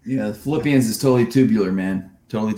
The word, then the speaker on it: Philippians: No, you see the tattoo, Philippians. You're like, what? Philippians? – Philippians. Say it just like that.